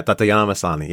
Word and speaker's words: Tatiana Maslany: Tatiana Maslany is, did I say that right? – Yeah, Tatiana Maslany, yes.